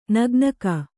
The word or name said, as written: ನಗ್ನಿಕೆ